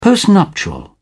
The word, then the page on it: postnuptial: British English